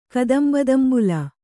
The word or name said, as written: ಕದಂಬದಂಬುಲ